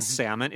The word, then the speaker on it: salmon: Unfortunately, even though the spelling was changed, the French-influenced pronunciation without an L remained. So the L in the English word salmon is silent.